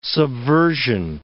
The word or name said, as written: subversion